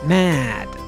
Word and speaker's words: mad: 今天我们学习一句话，这句话里面有一个非常重要的词是 mad， M-A-D，注意中间字母 A 的发音， mad， mad， mad 就是疯掉的。